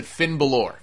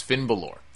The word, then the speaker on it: Finn Balor: Turns out it was Prince Devitt going under his new name, and they pronounced it Finn Balor. So that's what I'm going to pronounce it as, Finn Balor.